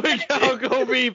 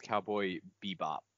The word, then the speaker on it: Cowboy Bebop: For anyone who doesn't know, it's Cowboy Bebop, not...